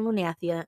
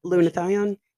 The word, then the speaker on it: Lunathion: Yes, that wasn't happening in Lunathia. – Right. – See, here we go. – Lunathion.